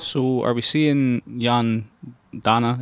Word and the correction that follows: Jan Danda: is that how you pronounce it, you said? – Jan Danda, yeah.